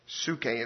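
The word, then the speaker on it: psuche (ψυχή): Psuche, it's spelled P-S-U-C-H-E. P-S-U-C-H-E. And it's pronounced psuche.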